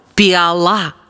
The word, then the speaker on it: піала: піала чи піала? Як правильно? Правильно, піала. Наголос на останнє «а».